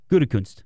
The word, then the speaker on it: Gutekunst: How do you say it? – Gutekunst.